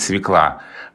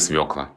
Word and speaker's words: свекла: Есть, знаешь, э, распространенное мнение, что давно уже правильно говорить свекла. Вот нет, по-прежнему правильно свекла.